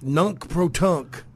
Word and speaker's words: nunc pro tunc: Um, it's a how is that pronounced? – Nunk pro tunk.